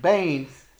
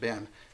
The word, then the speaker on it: ben: Now, what's interesting about this particular word is when you subdivide it, Bain means son. That's Ben. We sometimes pronounce it Ben, but Bain means son.